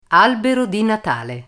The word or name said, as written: albero natale